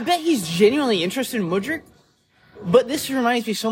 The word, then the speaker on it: Mudryk: Mudryk. – I need to get this right. – Mudryk.